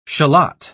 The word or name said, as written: shallot